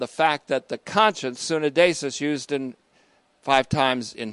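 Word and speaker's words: sunedesis (συνείδησις): First, Lonergan, specifically regarding the conscience, which we've seen in the Greek as sunedesis, S-U-N. We've seen this before, S-U-N-E-I-D, long E-S-I-S, sunedesis, sunedesis. That's conscience.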